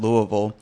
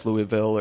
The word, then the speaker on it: Louisville: So I started off saying Louisville. I think right. That's the way I said it. And then my wife just corrected me over and over again till I said Louisville. But then it turns out I get up there and everyone says Louisville or something like that.